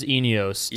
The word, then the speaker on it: Eneos: I actually talked with the Eneos CEO and asked him how to pronounce the company name. And I know you worked at Chip Ganassi Racing. – It is Eneos yes.